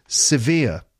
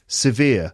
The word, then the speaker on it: severe: Severe. Two syllables. Via, the second syllable, is the stressed one. Severe. A severe headache.